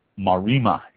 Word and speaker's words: Maremma: So the Marima area is spelled M-A-R-E-M-M-A, Marima area of Italy.